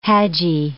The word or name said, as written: hajji